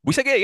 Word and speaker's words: Bouïssaguet: Bouïssaguet, yeah, that's good.